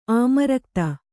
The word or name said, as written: ಆಮರಕ್ತ